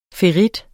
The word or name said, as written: ferrit